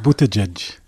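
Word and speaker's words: Buttigieg: ובדקתי גם את פיט ג'י, שאני אף פעם לא מצליחה לבטא את שמו. בבקשה, אתה תעשה את כן, זה, ניתן לך את כן, הבמה. כן, אתה יכול? בוטג'ג'. – אה, אוקיי, הייתי קרובה.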